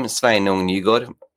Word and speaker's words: Sveinung Nygaard: It's a Norwegian name, Sveinung Nygaard. I'm based in Gothenburg in Sweden, but I'm from Norway. So I'm used to my name being pronounced in different ways, hence I go by Sven abroad.